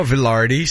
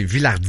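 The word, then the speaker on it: Vilardi: Il évoluait sur un trio formé de Mark Scheifele et Gabriel Villardi. Pardon. – Villardi. – Ouais, j'avais envie de dire Villardi comme les commentateurs français. – C'est pas Villardi, c'est Villardi.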